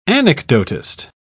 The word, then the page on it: anecdotist: British English